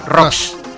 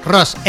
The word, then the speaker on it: Rocks: Rocks. Ross, R-O-S. – No, R-O-C-K-S. Rocks. – Entonces, no es Ross, tú.